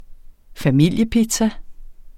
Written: familiepizza

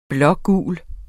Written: blå-gul

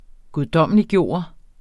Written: guddommeliggøre

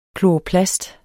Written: kloroplast

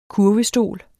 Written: kurvestol

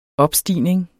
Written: opstigning

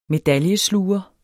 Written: medaljesluger

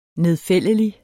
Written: nedfældelig

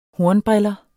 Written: hornbriller

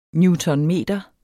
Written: newtonmeter

